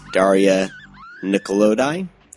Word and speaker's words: Daria Nicolodi: Now, this was starring Jennifer Connelly and Donald Pleasance, as well as Daria Nicolodi. Did I pronounce that correctly? – Yeah.